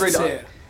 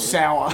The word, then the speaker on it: Saor: And I've heard 50 different pronunciations for that. – The Sear? – No, it's Sear, S-A-R. So it means it means free in Scottish Gaelic. But it's just like people look at the word and they're like... – Wait, S-A-O-R? – Yeah, and it's pronounced Sear. – Yeah, I'd read that as like Sayor or something. – Or Sour.